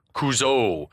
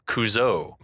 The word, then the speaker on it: Kuzo: Kuzo is a hard ass, telling them to address him as coach or mister, and then he pronounces it Kuzo. And it was at this moment that we realized that the decision had been made at some point to accent the final vowel, Kuzo.